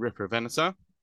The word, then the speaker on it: Riparovenator: We actually CT scanned both Ceratosuchops and Riparovenator. I just realized I'm pronouncing it Riprovenator, where it should be Riparovenator or Riparovenator.